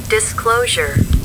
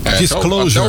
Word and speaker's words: Disclosure: Disclosure. – Tira o BG, por favor. Isso. – Vamos lá. – Disclosure. – Disclosure.